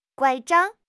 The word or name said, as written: 拐杖